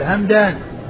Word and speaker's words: همدان: فما كان نسبة إلى قبيلة فهو بالميم والدال, الميم الساكنة والدال همدان.